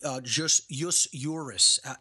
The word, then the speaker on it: jus juris: Jus means just, J-U-S in Latin means law. You know, jus juris is how it's pronounced in Latin.